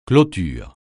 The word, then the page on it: clôture: francais